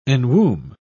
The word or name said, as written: enwomb